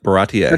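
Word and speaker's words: Baratie: Baratier, am I saying that correctly? – Yep, Baratier.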